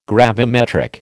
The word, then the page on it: gravimetric: American English